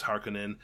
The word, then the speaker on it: Harkonnen: But um, yeah, I mean, I've been listening to the audio audiobook, and that's how they say it is Harkonnen, and that's what got me thinking about it in the first place.